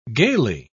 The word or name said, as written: gayly